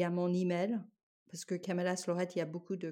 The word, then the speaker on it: Camellas Lloret: Moi, je le prononce Camelas Lorette, c'est parce que j'ai répété euh, ce que, ce que Annie m'a dit.